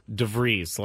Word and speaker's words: De Vries: The official pronunciation is, of the last name, is De Vries, like De Vries.